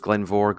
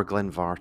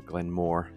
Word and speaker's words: Glen Mhor: known as Glenvor, Glenvar technically, or Glenmore if you can't be bothered pronouncing it.